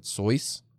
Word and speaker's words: Seuss: Also, by the way, for folks who don't know, he actually pronounced it Soyce.